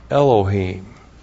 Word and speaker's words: Elohim: And then we have Elohim. Aleph, E-L-O-H, long I am. Elohim.